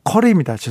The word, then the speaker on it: 커리: 한 번은 카레집에서 커리입니다. 죄송합니다. 아, 발음이 또.